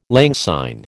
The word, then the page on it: lang syne: American English